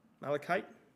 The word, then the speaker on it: malachite: Malachite.